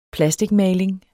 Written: PLASTIKMALING

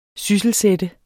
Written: SYSSELSATTE

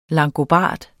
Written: longobard

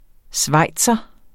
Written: schweizer

